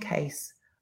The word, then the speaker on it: Kase: Am I saying that right? Is that Kase? – Yes, it's supposed to sound like just in case. – I love that. Enter Jestin Kase, a foster kid on the run in Chicago.